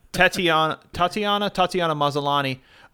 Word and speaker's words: Tatiana Maslany: Tatiana Maslany is going to be She-Hulk.